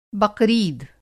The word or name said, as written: बक़रीद